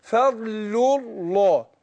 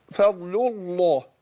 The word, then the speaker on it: فضل الله: فاقول فضل الله، يبقى انا لامين ورا بعض، سقط الالف، الالف بتاع الله اللي هو همزه الوصل، ونطقنا لامين ورا بعض. اقول فضل فضل الله هكذا.